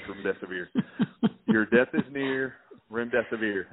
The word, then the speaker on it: remdesivir: Remdesivir, your death is near. That's how you pronounce it.